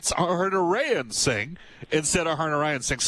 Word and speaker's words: Harnarayan: It is pronounced Harnarayan, but yeah, there is an extra A in there that throws it off.